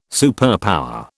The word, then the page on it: superpower: British English